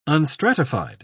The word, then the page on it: unstratified: British English